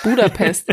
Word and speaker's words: Budapest: Budapest wird ja eigentlich Budapest ausgesprochen, ne? – Ja.